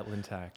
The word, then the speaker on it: Lintack: Yeah, Lintack.